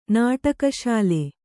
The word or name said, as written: ನಾಟಕಶಾಲೆ